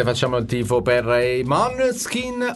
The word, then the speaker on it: Måneskin: Protagonisti per quanto riguarda l'Italia, l'abbiamo detto, i Maneskin, che in realtà cioè il gruppo, il nome del gruppo si pronuncia Måneskin, perché danese significa chiaro di luna.